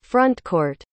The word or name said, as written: frontcourt